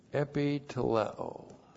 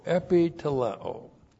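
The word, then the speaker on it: Epi teleo (ἐπιτελέω): Epi teleo. E-P-I-T-E-L-E long O. Epi teleo.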